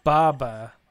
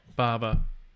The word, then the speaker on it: Baba: Ponda Boda. Boba. – Baba. – Baba.